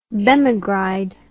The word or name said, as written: bemegride